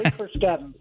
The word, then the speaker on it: Carskadon: Well, it's close, but that's okay. It's Trey Carskadon.